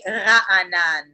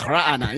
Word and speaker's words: Raanan: It's actually Raanan. – Yeah, you got to have you got to be able to make a guttural sound to correctly pronounce my name. I can't do it. It's like Raanan. Yeah, you got it, kind of.